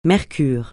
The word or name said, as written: mercure